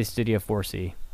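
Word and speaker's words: Studio 4°C: Studio 4 Degrees C? – I'd say Studio 4C.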